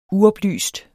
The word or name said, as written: uoplyst